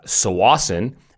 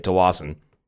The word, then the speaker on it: Tsawwassen: So this little piece of America right by, Tsawwassen, and that's how you say it, even though really we say Tsawwassen.